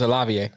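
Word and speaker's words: Olave: And the quarterback they get matched up with, and then you got to add Olav and Wilson in too from Ohio State. – Yeah, Olavier. – I've been saying it wrong. – Yeah, it's Olavier.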